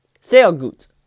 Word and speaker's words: sehr gut: Sehr gut. That's how you say it in German. Sehr is very, gut is good. G-U-T. Sehr gut. S-E-H-R-G-U-T, sehr gut.